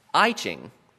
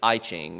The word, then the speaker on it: I Ching: But that phenomenon has only made the traditional English rendering even more confusing, making it more likely that the uninitiated will pronounce it I Ching instead of I Ching. And that more familiar I space C-H-I-N-G